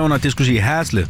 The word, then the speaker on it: Haslev: Og vi skal byde velkommen til dagens deltager, Haslev. Hej med dig. – Hej, Anton. – Udtalte jeg det rigtigt nok, Haslev? – Hej, Haslev. Ja, okay.